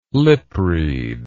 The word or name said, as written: lipread